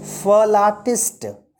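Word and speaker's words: philatelist: फलाटिस्ट फलाटिस्ट exact spelling जो है वो है P H I L A T E L I S T लेकिन पढ़ेंगे क्या फलाटिस्ट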